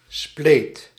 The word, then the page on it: split: [ˈsplɪt]; American English